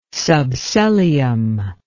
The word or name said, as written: subsellium